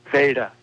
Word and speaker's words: Veda: I am CJ Liu, and today we have Philip Goldberg, who's the author of American Vita. Did I pronounce that right, Philip? Is it Vita or Veda? – Veda. – Veda. None of them. Veda. Okay, like Darth Vader.